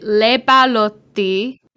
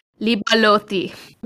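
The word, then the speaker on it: Leppalúði: That's phonetically how it told me on Wikipedia, but it's spelt like L-E-P-P-A-L-U with a little thingy on it. Something that looks kind of like an O and then an I, but it's pronounced Le Balotti.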